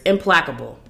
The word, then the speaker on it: implacable: I was like, is that how you say that? Yes. I know how to read. I know how to pronounce words. Implacable.